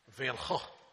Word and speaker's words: van Gogh: It actually, van Gogh.